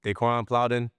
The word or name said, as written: Daeqwon Plowden